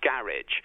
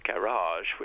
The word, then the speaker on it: garage: It's interesting, your pronunciation of that is quite different now to the most common English one, garage, because in English, that word has been fully nativized, so now it sounds more like an English pronunciation, whereas originally, of course, it would have been closer to your own pronunciation of garage, which is closer to the French one.